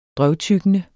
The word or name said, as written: drøvtyggende